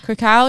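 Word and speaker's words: Kraków: Krakow, is that how you say it? – Krakow, yeah.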